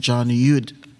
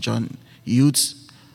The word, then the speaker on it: Eudes: Today, we celebrate St. John Eudes. You know, some say John Eudes, some say some John Utes, however way you want to pronounce it.